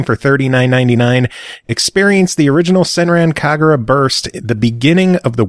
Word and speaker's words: Senran Kagura: All right, well, the first game that we're going to talk about is called Senran Kagura. Did I say it right? – Yes, yeah, Senran Kagura. You got it.